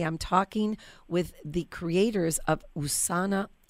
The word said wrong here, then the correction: Esona: Am I saying that right? – Esona Organics. The name, comes from Essence of Nature, Esona.